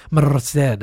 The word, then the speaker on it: Merced: She was like, "You have to roll your R." Merced.